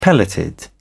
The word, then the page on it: pelleted: British English